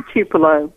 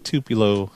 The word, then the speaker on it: Tupelo: That's how you pronounce it. Yes, in Tupelo. Mm-hmm. – Yep, Tupelo.